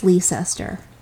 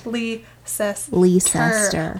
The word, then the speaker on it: Leicester: And by the way, it's Leicester. Bring it. – Leicester. Look at how it's spelled. You're missing a whole freaking syllable. – Exactly. Leicester.